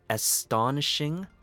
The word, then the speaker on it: astonishing: For the first word, we have astonishing. Four syllables. Us actually has the short U sound. Ston sounds like the word on, but with a ST to support it.